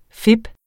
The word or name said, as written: fip